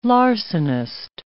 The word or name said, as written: larcenist